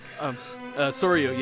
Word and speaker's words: Soryu: Is that how you say it? Soryu? – Soryu, yeah. Asuka Langley Soryu.